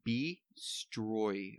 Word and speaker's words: Bstroy: It's a company or brand named B-Stroy, I guess is how you say it. – Say it again. – B-Stroy. I think I got it. B-S-T-R-O-Y.